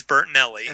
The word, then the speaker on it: Bertinelli: It is Bertinelli.